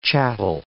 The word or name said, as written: chattel